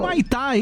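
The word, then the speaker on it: Humaitá: Cidade é o Maitá. O Maitá. – O Maitá, isso mesmo. Falei certo. O Maitá, Amazonas.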